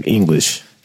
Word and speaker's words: Inglish: Chuck English.